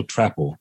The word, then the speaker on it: Trapple: Oh, it's Sarah Menzel Trapple. – Trapple. Okay. It was the Trapple. – It rhymes with apple.